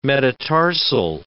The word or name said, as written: metatarsal